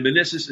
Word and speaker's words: Minisis: And Minissis is spelled M-I-N-I-S-I-S, like Man Isis.